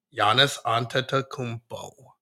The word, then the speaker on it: Giannis Antetokounmpo: Giannis Antetokounmpo.